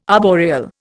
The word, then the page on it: arboreal: Indian